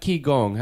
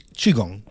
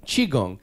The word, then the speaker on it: Qigong: And we've talked a lot about It's not Qigong. How do you say it? – Mm-hmm. Qigong. – Qigong.